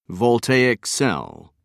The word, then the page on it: voltaic cell: American English